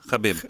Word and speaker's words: Khabib: Ja, då kommer vi till det här med hur vi uttalar förnamnet på Nurmagomedov vi har sagt Khabib. Ja, ja. Khabib. – Khabib. Mm. Det ska vi tänka på i framtiden.